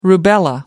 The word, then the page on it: rubella: American English